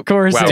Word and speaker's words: Coruscant: Wait, he's going to say Corsica. Did you just mispronounce Coruscant on the Star Wars and Jace podcast? – Wow. Coruscant.